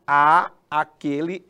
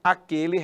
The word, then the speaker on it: àquele: Isto tudo para evitar a pronúncia. Todos irão a aquele restaurante. Para que você não repita a, a, você vai usar um acento grave no a de aquele e pronunciar apenas assim. Todos irão àquele restaurante.